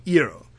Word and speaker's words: Iroh: But, like, that is how it would be pronounced in Japanese. And, like, in my brain, this is, like, an Asian culture. And so I, like, default to the Japanese pronunciation, yeah, which is the one that I have a passing familiarity with. And so it would be Iroh.